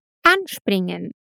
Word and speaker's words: anspringen: Outro detalhe: com verbos separáveis, a tônica, a parte mais forte da sílaba, recai no prefixo anspringen.